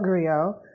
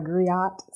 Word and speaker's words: griot: Sure, yes, I call it griot. – Yeah. – Some people colloquially will call it a griot. So if you hear griot, don't judge, just translate to griot.